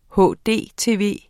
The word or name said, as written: hdtv